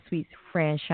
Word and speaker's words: Suite Franchon: So, everybody out there listening in this virtual world, my name is Sweet Franchon,